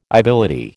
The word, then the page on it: ibility: American English